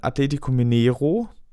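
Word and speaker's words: Atlético Mineiro: Äh, ein Gegenspieler von Atletico Mineiro, habe ich es richtig ausgesprochen? – Ja, das war absolut richtig.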